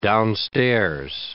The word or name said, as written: downstairs